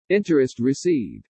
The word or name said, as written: INTEREST RECEIVED